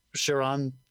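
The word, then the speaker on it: Charon: We have Ian McShane not protecting, but losing Sharon. Is that how you pronounce it? Yeah.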